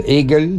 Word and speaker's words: Agle: On a parlé de Eagle, donc ça s'écrit A-G-L-E.